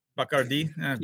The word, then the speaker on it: Bacardi: No, you have to say it right. You have to say it right. – Bacardi. – Bacardi.